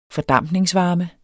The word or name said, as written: fordampningsvarme